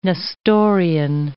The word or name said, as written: Nestorian